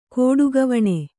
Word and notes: ಕೋಡುಗವಣೆ: kannada

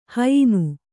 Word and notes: ಹಯ್ನ: hayna

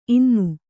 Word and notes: ಇನ್ನೂ: innū

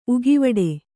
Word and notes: ಉಗೆವಾಡ: Kannada

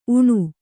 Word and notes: ಊನ: ūna

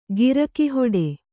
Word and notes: ಗಿರಕೆಹೊಡೆ: girake hoḍe

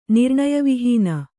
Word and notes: ನಿರ್ಣಯವಿಹೀನ: kannada

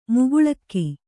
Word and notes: ಮೋಕಳೀಕ: mōkaḷīka

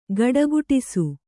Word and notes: ಘುಡುಘುಡಿಸು: Kannada

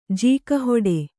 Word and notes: ಜೀಕಹೊಡೆ: Kannada

